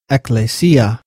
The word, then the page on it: ἐκκλησία: Ekklēsia; Biblical Greek